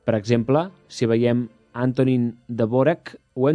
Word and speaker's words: Dvořák: És el cas de Dvořák, que sabreu que s'ha de pronunciar com Antonín Dvořák.